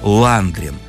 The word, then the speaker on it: Ландрин: Так вот, все дело в том, что у Феди того фамилия была Ландрин. Только с ударением на первый слог.